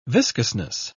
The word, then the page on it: viscousness: British English